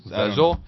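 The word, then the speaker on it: Zezelj: It's Z-E-Z-E-L-J. How do you pronounce Zezel? – Zezel? – It's Warren Ellis and Steve Smith.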